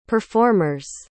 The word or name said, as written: performers